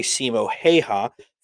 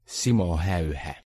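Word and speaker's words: Simo Häyhä: So where I say Simo Heha, Finnish people say Simo Heha.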